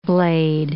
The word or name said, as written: blade